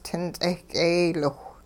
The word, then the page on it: tin-ts'eh k'eeyliwh: Hupa